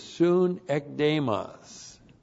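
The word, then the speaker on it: soon ekdemos (συνέκδημος): And that we have next, the key word is soon ekdemos. Soon ekdemos. Looks like that in the Greek. S-U-N-E-K-D, long E-M-O-S. Soon ekdemos.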